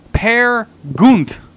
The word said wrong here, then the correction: Peer Gynt: Peer Gynt, sweet morning.